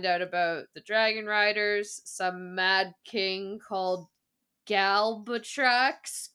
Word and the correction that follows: Galbatorix: who lost his dragon. Oh, what? How do we say it? – Galbatorix. – There we go.